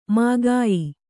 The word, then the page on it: ಮೂಗೆಯ್: mūgey